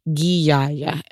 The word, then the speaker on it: Ghiaia: For those who are confused about how it is pronounced, it's Ghiaia.